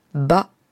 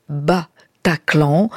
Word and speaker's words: Bataclan: et le titre s'appelle « Bataclan ». Clan, avec les tirés entre les trois syllabes, Bataclan, les monosyllabes qui veulent croire qu'il s'agit d'un mot chinois, Bataclan, qui signifiait ici donc le grand remue-ménage.